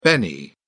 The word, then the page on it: benny: British English